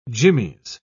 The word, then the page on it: jimmies: British English